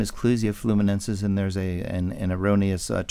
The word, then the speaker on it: Clusia: Okay, so I pronounced it wrong. Clusia. – Clusia, yes, it's Clusia.